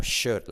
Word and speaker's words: should: Cuidado la SH, you should. U como o, should. La L no se pronuncia.